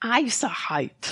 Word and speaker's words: isohyet: A ver, esta palabra que se escribe Isoyet. Pero se dice Isahite, que es eh, Hiet, o sea, escrito I-S-O-H-Y-E-T isoyet.